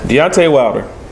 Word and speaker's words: Deontay: Bro, I don't. Deontay Wilder. – Deontay, I call him. Is it Deontay? That's his name. – It looks like it's spelled D-E-O-N-T-A-Y. – Yeah, that's Deontay.